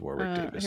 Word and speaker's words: Warwick Davis: Wait, am I saying that right? I want to get this right. He's a little person. – Oh, he played, he was in Hogwarts. He was in, it is Warwick Davis.